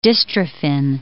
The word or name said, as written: dystrophin